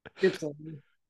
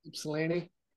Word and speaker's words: Ypsilanti: Ypsilanti. – What? Say it. – Ypsilanti.